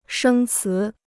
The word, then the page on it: 生词: shēng cí